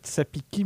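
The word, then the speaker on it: Tsapiky: Pour les gens qui voudraient faire des recherches après aussi sur Internet et tout, ça s'écrit. Tsapiki, mais ça se dit Tsapik, donc ça veut dire que t'as des petites notions de malagasy aussi maintenant. – Oui, c'est que en fait ils disent pas trop les, enfin en l'occurrence ils disent plutôt Tsapik, ouais, et de façon générale un Y à la fin du mot il est pas prononcé, ouais.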